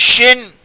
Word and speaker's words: Shin: Shin is pronounced as Shin, with like an SH sound in English.